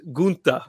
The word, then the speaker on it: Gunther: So the German pronunciation is Gunther.